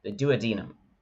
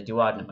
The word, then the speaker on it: duodenum: The stomach opens up, and now we're admitted into the duodenum or the duodenum. I'm still working on pronunciation, and I'm working with international doctors, so sometimes I get different opinions.